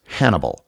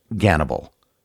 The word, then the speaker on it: Gannibal: The name he chose was Hannibal, or as it's pronounced in Russian, Gannibal.